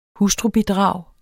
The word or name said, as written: hustrubidrag